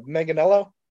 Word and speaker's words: Manganiello: I can never pronounce his name right. Manganiello? – Manganiello.